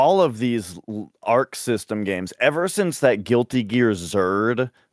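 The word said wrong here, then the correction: Xrd: I think it's pronounced Exard.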